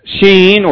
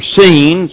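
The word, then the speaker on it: shin: Now, the letter sheen or seen, sometimes it's pronounced as an S like it was there, but usually it's like SH.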